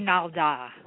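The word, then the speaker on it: Kinaaldá: Navajo Canalda. Am I saying that right? Canalda ritual? – It's actually Kinalda.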